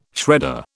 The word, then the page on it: shredder: British English